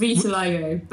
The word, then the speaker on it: vitiligo: With your vitiligo, is it vitiligo? Is that how you pronounce it? – Yeah, With, vitiligo.